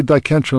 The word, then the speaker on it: Dicentra: I may have a hard time retraining myself in terms of the pronunciation of Dicentra. – I know. – So, me too. It's like clematis and clematis. – Right. – Say Dicentra and people will think you're wrong.